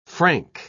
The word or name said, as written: frank